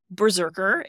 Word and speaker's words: BRZRKR: Berserker. It's spelled really weird. – Cool. – B-R-Z-R-K-R. There are no vowels in this.